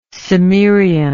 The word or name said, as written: cimmerianism